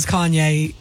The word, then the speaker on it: Kanye: Kane West. – I'm thinking it's Kane West. – Kane West. Not only did she mispronounce Kanye, it was also a song that's been guessed twice before.